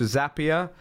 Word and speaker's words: Zapier: And you're also the founder of MakerPad, which was, you know, the leading no-code platform, got sold to Zapier. Rhymes with happier.